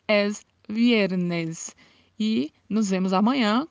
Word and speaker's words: viernes: Esta pronúncia de viernes é uma pronúncia um pouco diferenciada, porque no espanhol o V tem um som de B. Então pronunciamos não viernes, mas pronunciamos viernes, como se quiséssemos falar a letra B, como se quiséssemos falar viernes, mas não é um B muito fechado, é viernes.